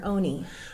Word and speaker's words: Ona: and Ona Judge. And is her name pronounced Ona or Oni? – Oni was the child name, I think Ona is what we want to call her in a respectful sense.